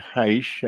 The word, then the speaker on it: Haisch: Now we have two new guests to explore this with, a remarkable man and woman, Bernie Haisch and Marcia Sims. Am I pronouncing your name right, Bernie? – Haisch is fine, yes.